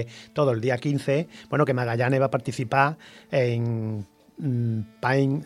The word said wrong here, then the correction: Pint: a ver si lo digo bien, of Science. – Pint of science.